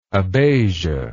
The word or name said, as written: abatic